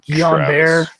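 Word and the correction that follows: Guillain-Barré: syndrome. Yeah, Travis I can say Travis Frederick. – Travis Frederick. It's the auto, autoimmune disease. – Guillain-Barre.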